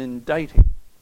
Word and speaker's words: indicting: I will say this secondly, that we have in this word an indicting. An indicting. That word indicting is a little bit strange. It's the only word I know of that has the letter C in it that you don't pronounce.